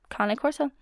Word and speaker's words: Cane Corso: How do I pronounce Cane Corso? Well, I just said it, Cane Corso. That is how you pronounce it. Not Cane Corso, not Cane, Cane, Cane Corso. They are an Italian dog breed, so it's pronounced a bit differently than how it's spelled.